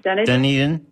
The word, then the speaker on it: Dunedin: Dunedin, yeah.